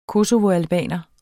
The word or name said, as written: kosovoalbaner